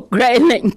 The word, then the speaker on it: Grayling: I don't know if it's Grayling or Grayling Caroline, which is the proper pronunciation. – It's Grayling, thank Grayling.